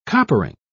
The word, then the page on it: coppering: British English